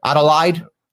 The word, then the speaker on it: Adelaide: Adelaide, Australia.